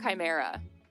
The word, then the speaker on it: chimera: Yeah, a chimera. – Chimera, thank yeah. you. I've been having trouble with the CHI, pronouncing it as chi instead of ch.